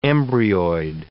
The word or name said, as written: embryoid